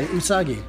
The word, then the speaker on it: Usagi Yojimbo: Usagi Yojimbo? – Yeah, Usagi.